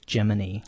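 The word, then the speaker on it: Gemini: Official pronunciation, but evidently the astronauts like Jim Lovell and Ed White did say Jiminy.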